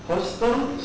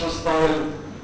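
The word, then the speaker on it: hostile: هوستل صار مكتوبه هوستل سيرفايكال ميوكس مش هوستايل. احنا بننطقها طبعا هوستايل.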